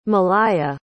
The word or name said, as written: maliah